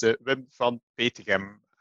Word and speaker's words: Wim van Petegem: And you pronounced my name pretty well. So it's Wim van Petegem.